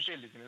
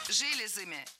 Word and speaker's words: железами: Янтовидные собаки обладают довольно густым мехом и обладают сильными пахучими железами. Ну, то есть, соответственно, животное будет издавать неприятный запах. – Железами. Это неверно. Правильный вариант – железами, железам, железах. В этом слове тоже ударение всегда падает на окончание.